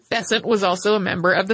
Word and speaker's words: Besant: In 1888, the Bryant and May match factories came to the attention of Annie Besant. Most American pronunciations of this seem to rhyme with Crescent, so we're going with that.